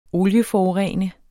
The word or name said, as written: olieforurene